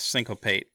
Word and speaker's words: Syncopate: Yes, Syncopate, which has, as long as we're talking about the flavor of what cards do, most counter spells are kind of dismissively clever.